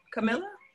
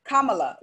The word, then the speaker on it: Kamala: Camilla? – Camilla as in commit, but it's Kamala. Like, I think it's, we're going to have to figure that shit out when she becomes vice president.